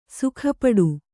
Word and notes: ಸಿಕ್ಕಾಪಟ್ಟೆ: sikkāpaṭṭe'

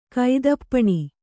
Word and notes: ಕುತ್ತುಪೆನ: kuttupena

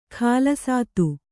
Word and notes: ಖಾಲಸಾತು: khālasātu

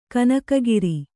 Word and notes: ಕನಕಗಿರಿ: kanaka giri

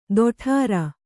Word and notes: ತೊಡರ್: toḍar

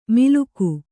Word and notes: ಮಿಲುಕು: miluku

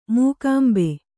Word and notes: ಮೂಕಾಂಬೆ: Kannada

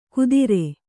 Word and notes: ಕುದಿರೆ: Kannada

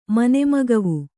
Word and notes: ಮನೆಮಗವು: Kannada

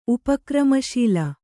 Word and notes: ಉಪಕ್ರಮಶೀಲ: upa kramaśila

